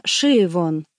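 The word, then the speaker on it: Siobhan: Вообще-то пишется с «Б», но произносится «Шивон». Однако «Б» где-то присутствует».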